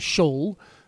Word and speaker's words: Scholl: I think the German pronunciation is Scholl. Am I right? There we go.